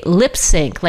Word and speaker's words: Lips Inc.: I thought they were called Lips Incorporated, but it's actually Lip Sync, like lip syncing. Lips Inc. is how they pronounce their name.